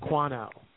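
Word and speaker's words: Quanna: It's Q-U-A-N-N-A, and it's pronounced Quana.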